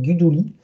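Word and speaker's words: Guidugli: Alors, il a un nom italien, mais qui se prononce pas l'italienne, Guduli, euh, qui est euh, bah, bien soutenu par les joueurs.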